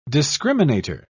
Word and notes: discriminator: British English

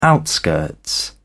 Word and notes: outskirts: British English

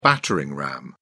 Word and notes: battering ram: British English

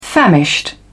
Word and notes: famished: British English

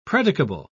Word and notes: predicable: British English

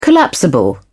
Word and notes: collapsible: British English

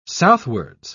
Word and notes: southwards: British English

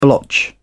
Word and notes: blotch: British English

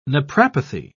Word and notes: naprapathy: British English

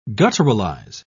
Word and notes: gutturalize: British English

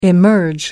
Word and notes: emerge: American English

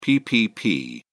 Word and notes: ppp: British English

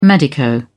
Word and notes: medico: British English

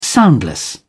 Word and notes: soundless: British English

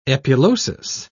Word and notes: epulosis: British English

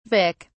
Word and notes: vic: British English

